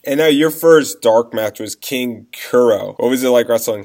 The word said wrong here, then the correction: King Cuerno: King Cuerno, man. King Cuerno.